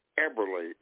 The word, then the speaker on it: Eberly: Okay, my address is ed underscore Eberly, E-B-E-R-L-Y.